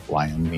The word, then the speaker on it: Lion: How do you say Lion? Leon. – Lion. – Lion. Yes. You never know if French stuff sneaks into English. I get so confused. So G-A-B-R-I-E-L-L-E.